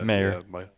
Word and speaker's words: Mayer: Is that Meyer, Michael Meyer? – Mayer.